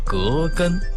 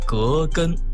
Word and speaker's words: 葛根: 说出来您肯定会很惊讶，这个中成药啊，只有一个成分，就是葛根。说到葛根这个中药，我们再来说一下它的读音。我们身边有很多朋友都把葛根读作葛根，姓葛的那个葛。